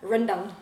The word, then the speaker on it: rendang: How do you say it? Rendang. Run. Say again. Rendang.